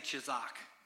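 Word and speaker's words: chizak (חזק): And I don't know Hebrew, but it comes from a Hebrew word, and that word is chizak. Everybody say chizak. That's kind of fun, isn't it? It's spelled C-H-A-Z-A-Q, chizak.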